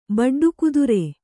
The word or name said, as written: ಬಡ್ಡುಕುದುರೆ